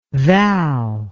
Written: val

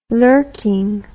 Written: lurking

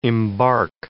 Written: embark

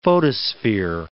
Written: photosphere

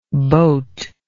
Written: boatless

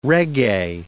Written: reggae

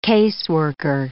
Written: caseworker

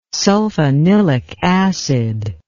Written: sulfanilic acid

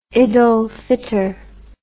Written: id ul fitr

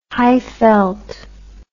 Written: Highveld

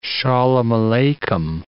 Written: Aleichem

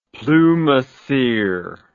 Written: plumassier

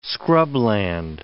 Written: scrubland

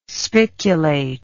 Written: spiculate

